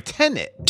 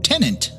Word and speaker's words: Tenet: Tenet. – Tenant. – Hold on here. Let me look. – Tenet. Tenet. Tenet. It's like Tenet. – Tenet. Yeah, Tenet. – Yeah. – Not Tenant. Tenet.